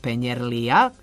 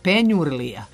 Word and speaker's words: penjurlija: U Vranju pomenuto pecivo sa sirom ipak ne zovemo penjerlija, kažete vi da je akcenat takav, već penjurlija sa akcentom na u.